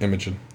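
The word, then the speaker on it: Imagen: Is that how you say it? Imogen? – Yep. Imogen.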